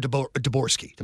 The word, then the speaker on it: Dvorsky: Dvorsky.